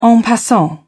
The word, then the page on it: en passant: British English